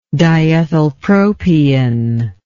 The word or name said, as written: diethylpropion